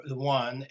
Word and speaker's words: yuan: renminbi, or yuan, as it's pronounced, Y-U-A-N.